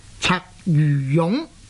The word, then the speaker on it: 鰂魚涌: 我時時都講啊，例如香港東區有一個地方，佢嘅地名呢就叫做鰂魚涌，大家都知道的。但是如果照字典的注音呢，这样呢它的正確的讀音呢是應該呢是讀做鰂魚涌。